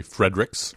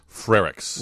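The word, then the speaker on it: Frerichs: They then wrote it down, F-E-R-R-I-S. – And the correct pronunciation is? – Frerick's.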